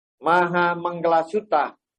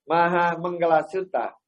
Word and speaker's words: Maha Mangala Sutta: Tụng đọc và thực hành Maha Mangala Sutta. Quý vị đọc theo thầy coi. Maha Mangala Sutta. Maha Mangala Sutta. Chắc kiếp trước quý vị có ở Ấn Độ. Phát âm chuẩn.